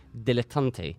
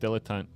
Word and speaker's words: dilettante: You're the silent guardian, a watchful protector, a 3SP dilettante. And for that, you get... Do you know what a dilettante is? – It's pronounced dilettante.